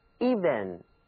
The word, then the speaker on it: even: even 这个词平坦的，既然它是表示平，咱们读的时候啊嘴唇呢也应该很平，所以你们应该把嘴唇轻轻的裂开，字母 e 读作长的 e, even E, even。